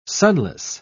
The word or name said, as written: sonless